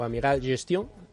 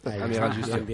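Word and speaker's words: Amiral Gestion: Pablo Martínez Bernal es, como todos ustedes saben, es el director de relación con inversores de Amiral Gestión, o Amiral Gestión, mejor dicho, ¿no? – Sí, un poquito mejor. – Amiral Gestión.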